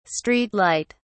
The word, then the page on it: street light: British English